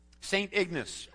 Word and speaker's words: St. Ignace: St. Ignace.